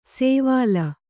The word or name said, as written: ಸೇವಾಲ